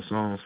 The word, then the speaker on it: Psalm: And remember, Psalms spelled with a P. P-S. The P silent. What language is that? Yeah, right. P-S-A-L-M. Spell Psalm. Pronounced Psalm, I guess. Yeah, say it like that. Psalm. Pronounced Psalm. P-S-A-L-M.